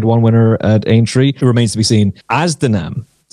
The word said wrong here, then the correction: Anzadam: another one, and I'm sure I'm after butchering that pronunciation, as Anzadam. – Anzadam.